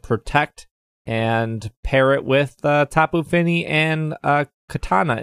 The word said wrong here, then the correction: Kartana: Is that how you say it? Kartana? Katana? – Kartana. Like the sword.